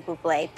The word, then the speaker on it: Bublé: You mean Bubbly. – No, I mean Bublé. – You're Michael Bublé. That's a bubbly. – She's right, Michael Bublé. It's pronounced Bublé. I know.